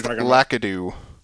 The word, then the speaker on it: Lakitu: Lakitu, his camera skills have gotten a lot better. – Yeah. – I believe it's pronounced Lakitu.